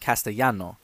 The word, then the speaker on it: Castellano: And you actually pronounce it Castellano, but they pronounce the double L as sh instead of ya.